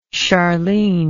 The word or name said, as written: sharleen